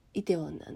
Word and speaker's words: 梨泰院: でイテウォンクラスとはどんなものなのかなって思った時にそもそも最初ね「イテウォン」って読めなかったんだけど「イテウォン」なんだねこれ。